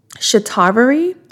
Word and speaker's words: Shatavari: Something else that I use is Shatavari. I'm going to spell it S-H-A-T-A-V-A-R-I. I'm going to spell it again. S-H-A-T-A-V as in victory. A-R-I, Shatavari, okay?